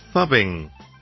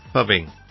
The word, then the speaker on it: phubbing: Fubbing. This is spelled P-H-U-B-B-I-N-G. Fubbing.